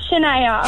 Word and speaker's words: Chenea: It's Chenea. – Chenea, like Shania Twain, who released a new single today. – Yeah, similar.